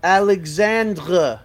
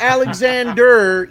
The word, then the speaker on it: Alexandre: Guys, it's Justin Alexandre. Not Alexander, E-R. It's spelled R-E at the end. That is Alexandre. French.